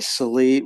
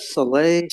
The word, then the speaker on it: Saleh: I like that guy, Salee. What, how do you how do you pronounce that? Saleh, Salih, Saleh, Yeah, the, the, the, good guy.